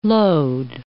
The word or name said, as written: lode